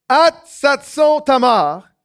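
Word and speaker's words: Hatsatson-Tamar: Ils sont déjà à, attention à la prononciation, Atsatson Tamar, c'est-à-dire en Engedi.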